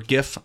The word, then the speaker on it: GIF: No, but also, later on I said, after I tweeted Ohio State minus 15 with that gif, or gif, I'll never know how to pronounce that the right way. – It's a soft G.